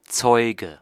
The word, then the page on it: témoin: France; /te.mwɛ̃/